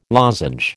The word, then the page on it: lozenge: American English